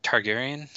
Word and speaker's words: Targaryen: How do you say that name? – Targaryen. – Targaryen from Game of Thrones.